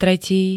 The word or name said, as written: tretí